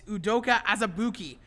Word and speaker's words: Udoka Azubuike: It's Udoka Azubuike.